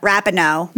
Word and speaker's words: Rapinoe: Rapinoe? I love her. Rapinoe.